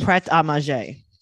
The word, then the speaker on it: Pret A Manger: Comes from French meaning ready to eat. Ready, Pret, to eat, manger in French. – Pret a manger.